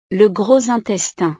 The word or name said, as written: le gros intestin